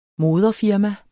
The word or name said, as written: moderfirma